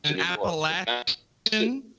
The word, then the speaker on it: Appalachian: or an Appalachia man who moves an Appalachian.